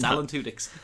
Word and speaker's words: Alan Tudyk: It's Alan Tudyk.